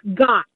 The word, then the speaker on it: Gaut: Okay, it's Gott. G-A-U-T pronounced Gott as in I got you.